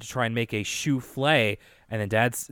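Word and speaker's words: soufflé: sort of short-lived joke around this part where DW doesn't know how to pronounce souffle. She keeps saying like sloofe and souffle. – Sloofy.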